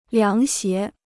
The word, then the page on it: 凉鞋: liáng xié